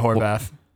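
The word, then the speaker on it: Horwath: Am I pronouncing your name right? How the fuck do you say your name? – Andrea Horvath. Yeah. – Horvath? – Yeah. The W is a V. Just think of it that way.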